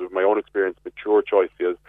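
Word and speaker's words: Choisya: A golden, is it choicea? – Yeah, you're spot on.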